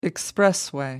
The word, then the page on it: expressway: American English